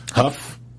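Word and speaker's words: Hough: It's this great special that our CEO and founder, Maria Menounos is hosting with, her good friend, Juliana Hoff. I never said that right. – Huff.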